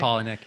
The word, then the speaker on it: Palahniuk: So one of them, Dennis Widmeyer, runs Chuck Palahniuk. – Yeah. That's how you say his name, Chuck, right? Palahniuk. Yeah.